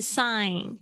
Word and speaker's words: sign: OK，接下来是 sign 这个 G 不发音，sign 是一个签名，是一个动作。